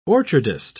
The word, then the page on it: orchardist: British English